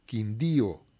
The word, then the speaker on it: Quindío: Área de desastre, destaca la reciente maestría en gestión de riesgo y desastre en la Universidad de Quindío, me lleva acento en la I, Quindío, y programa en la Universidad Nacional Abierta y a Distancia sobre el cambio climático y riesgo.